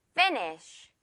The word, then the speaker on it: finish: 자, 그럼 첫 번째 단어 finny만 제대로 발음을 하고 쉬로 끝내주는 거예요. finny 느껴지나요, 여러분? 풍선과 실꼬리 finish 마지막에 살짝 자, 끝내다 라는 단어죠.